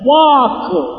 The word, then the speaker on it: walk: Walk. – Walk. – W-A-L-K. – W-A-L-K. – L ని మధ్య పోతారు walk అంటారు. Walk అని ఎందుకు? – L is silent.